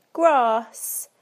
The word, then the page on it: grass: British English